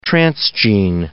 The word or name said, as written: transgene